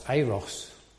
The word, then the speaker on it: Eros: I did read that it's correctly pronounced Eros. Eros, whichever.